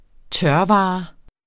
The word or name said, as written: tørvare